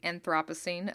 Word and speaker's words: Anthropocene: so i don't know if we've talked about the anthropocene on here or the anthropocene as brits say it much more cooler than we do